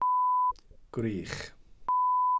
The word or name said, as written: gwrych